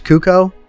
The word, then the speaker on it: Cuoco: Guests include Kaylee, oh my gosh, Cuoco. C-U-O-C-O. Am I saying that right? – C-U-O-C-O, sure.